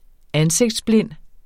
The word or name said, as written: ansigtsblind